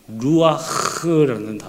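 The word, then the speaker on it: 루아흐 (רוח): 루아흐라는 단어입니다. 루아흐, 히브리어가 좀 이런 발음이 있어요.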